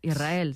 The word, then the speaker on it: Israel: Y la S con la R es difícil de pronunciar. La gente dice Israel. Es sin verdad. Que suene la S, Israel, sí, es difícil pronunciarse.